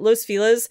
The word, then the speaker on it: Los Feliz: Los Feliz. I'm pronouncing it that way because that's how you pronounce it here in Los Angeles. Los Feliz.